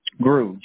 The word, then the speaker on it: Groulx: Grou, G-R-O-U-L-X. – You said G-R-O-U-L-X? – Yep, that's it. It's pronounced Grou. It's French.